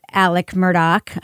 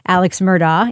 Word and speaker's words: Murdaugh: And we should mention, I think, for listeners who maybe have seen his name in headlines, it is pronounced Alec Murdoch and not Alex Murdaugh, even though that's how it's spelled.